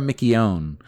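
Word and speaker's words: McKeown: I have to get her name spelled right or said correctly, because I said it wrong in the press conference, and I was told that I was saying it wrong. So it's Tara Michione.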